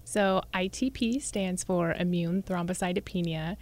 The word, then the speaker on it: immune thrombocytopenia: Start by telling us, did I come close to pronouncing immune thrombocytopenia correctly? – Yes, actually you did.